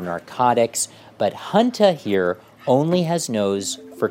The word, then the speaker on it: Hunta: No, I mean Hunta. It's like Hunter, but pronounced with a Boston accent, Regina, Hunta.